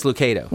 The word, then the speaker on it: Lucado: What's the right way? What does he say? – He says Lucado. – Well, then that's what it is. Lucado.